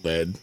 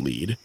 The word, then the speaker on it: Lead: Which is spelled like lead, and so idiots call it lead, and it's not. It's fucking Lead.